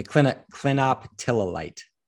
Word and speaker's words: clinoptilolite: And so as far as why is it that not all clinoptilolite, is that how we pronounce it properly? – Yeah, clinoptilolite.